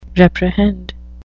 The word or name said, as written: reprehend